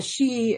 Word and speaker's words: sidhe: The she, it's spelled S-I-D-H-E, is actually like the fairy realm in Ireland.